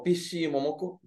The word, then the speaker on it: Peach Momoko: Bueno, es escrito y dibujado por Peach Momoko, que se pronunciaría como Pishi Momoko. Pishi Momoko. Pishi. O sea, no pinche. Pishi.